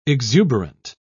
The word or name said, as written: exuberant